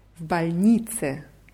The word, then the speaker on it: в больнице: В больнице. Die Betonung ist auf i.